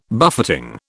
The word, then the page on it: buffeting: British English